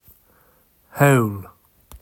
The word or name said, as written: Howl